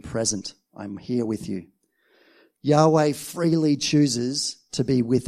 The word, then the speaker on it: Yahweh: In Hebrew, that name is pronounced Yahweh.